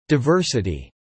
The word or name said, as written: diversity